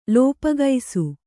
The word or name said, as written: ಲೋಪಗೈಸು